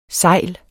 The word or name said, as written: segl